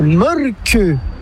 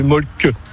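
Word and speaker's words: Mölkky: Inventé en Finlande en 1996, ce jeu de quilles s'écrit M-O-T-R-E-M-A-L-K-K-Y. – Ça s'écrit Mulky, mais ça se prononce Mulky. – Le Mulky. – Plutôt un E qu'un E, mais pas un U. Donc ça fait Mulky.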